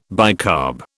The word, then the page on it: bicarb: British English